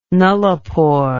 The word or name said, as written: nullipore